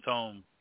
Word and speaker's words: tome: Must I go to the tomb to get the tome? – Tomb to the tome. – And I said it right that time.